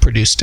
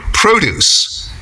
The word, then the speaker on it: produce: Oh, you know, and if you change the pronunciation of the word, if you change the emphasis and you make it produce, it has a completely different meaning.